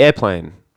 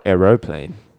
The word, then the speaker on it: aeroplane: Or aeroplane. – I was confused by that. Aeroplane or airplane? – I don't know yet. – I always say aeroplane, but I spell it airplane.